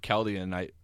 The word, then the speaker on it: Kaladin: I think of Kaladin. How do you say it again? How's Cal's name? – Kaladin. – Kaladin, I think of that song from Have Gun Will Travel. Paladin, Paladin, where did you go? – Yeah, I think it does sound like Paladin.